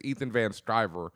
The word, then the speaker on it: Ethan Van Sciver: There's an X-Men issue that was published and released, and it features art by, I want to say it's Ethan Van Sciver.